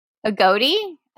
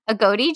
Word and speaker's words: agouti: How do you say the word A-G-O-U-T-I? – A-G-O-U-T-I? – A goatee? A goatee gene? A goatee? – Yeah. I would say a goatee.